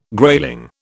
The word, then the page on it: grayling: British English